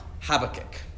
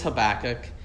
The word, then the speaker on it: Habakkuk: It is not Habakkuk. Some people pronounce it Habakkuk. You may hear that at times. It's not. It's Habakkuk.